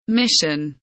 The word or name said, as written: mission